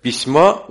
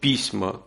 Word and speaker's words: письма: Нужно было сказать «письма», он сказал «письма», то есть он сделал ударение на первый слог.